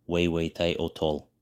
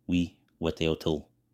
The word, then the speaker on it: Huehueteotl: The god Huehueteotl, pronounced Weiweiteotol, Weiweteotl.